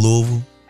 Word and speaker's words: Louisville: Are you calling from Louisville? – Absolutely.